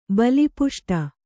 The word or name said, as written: ಬಲಿಪುಷ್ಟ